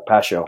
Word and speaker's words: Pasio: How do I say that name? – Pasio. – Pasio? All right. Pasio? – Yep.